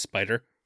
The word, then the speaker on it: SP/DR: I think it's just pronounced spider.